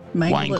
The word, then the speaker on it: wangle: Wangle. It it's sort of like Wang Chung, only you're wangling.